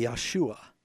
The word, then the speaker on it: Yeshua: If you were going to say Jesus' name in Hebrew, it would be Yeshua.